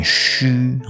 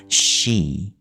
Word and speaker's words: she: 这个 she 女生的她，有很多朋友，他们都习惯念成虚哦，这个虚虚无的虚，呃，需要的需，其实它的正确念法是 she，它是 e 的长母音的。